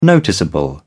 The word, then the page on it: noticeable: British English